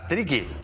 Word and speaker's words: triqui: Pues mira, hoy traemos a Carlos Guadalupe Hernández, conocido como Carlos CGH, que es de Oaxaca y proviene de la cultura triqui, que es, bueno, triqui se ha dicho así porque realmente es más complicada la pronunciación y al final hemos quedado con la cultura triqui.